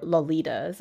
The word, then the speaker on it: loli: Because if an anime character says like, oh my gosh, you're a lolly, they're not going to say lolly, they're going to say loli.